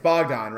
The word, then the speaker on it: Bogdan: But it's Bogdan, right? I don't want to screw up the pronunciation of Eastern Europeans. – Yep, you're right on that.